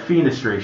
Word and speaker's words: fenestration: Penetration. Sorry, fenestration.